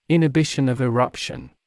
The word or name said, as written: inhibition of eruption